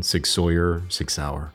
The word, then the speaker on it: Sig Sauer: I want to recognize Sig Sauer, and that's the correct pronunciation, Sig Sauer.